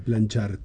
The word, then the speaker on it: Planchart: Como plancha, como plancha y termina art. – Art. – Como arte, planchart.